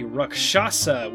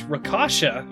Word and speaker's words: Rakshasa: Welcome to Monster Talk, episode 3, where we are going to talk about the Rakshasa, which I used to pronounce Rakasha all the time. Uh, that that uh, was clearly wrong.